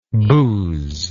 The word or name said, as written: bouse